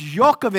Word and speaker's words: Jochebed: In Hebrew, it's pronounced Jochebed, and I'm going to say it that way throughout the passage, Jochebed.